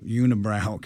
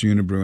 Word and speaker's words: Unibroue: It's spelled Unibroue, and I went Unibrow because it looks Unibrow, right? And it's like, no, it's Unibrew.